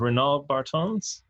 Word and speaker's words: Renaud Bartens: And we have joining us is Renaud Bartens.